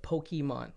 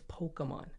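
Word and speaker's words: Pokémon: Well, you know what grinds my gears is the people that are trying to jump on the bandwagon that call it Pokemon. Like, if you're going to get in, at least try to get it right, right? That little accent on the E, it's Pokemon.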